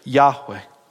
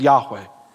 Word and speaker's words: Yahweh: But because they never spoke the name Yahweh, because they didn't want to take God's name in vain, we just have an educated guess as to how it's pronounced as Yahweh. Y-H-W-H.